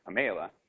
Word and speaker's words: PAMELA: Pamela is an Italian, Russian, Swedish collaboration, but since it's mostly identified with the Italian group at this moment, it's pronounced Pamela, and you have to gesture.